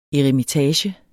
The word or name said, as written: eremitage